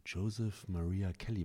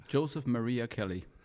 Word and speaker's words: Joseph Maria Kelly: Habe ich deinen Namen richtig ausgesprochen? – Ja, so heiße ich. Joseph Maria Kelly.